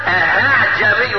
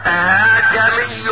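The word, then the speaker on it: ءَاعجمي: لكن النطق الصواب اعجمي وعربي. لن طعها همزه ولن طعها الف بين الهمزه وايه؟ والالف.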